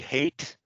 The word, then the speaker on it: Haidt: Jonathan, is it Haidt? Is that how you say his name? – I pronounce it Haidt. – Haidt, okay. – H-A-D-T, so it's not H-A-T-E, but H-A-D-T. Jonathan Haidt.